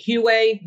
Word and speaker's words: Huawei: I'm not sure I'm pronouncing it right. Is it Huawei, the Chinese? – Oh, wait, yep.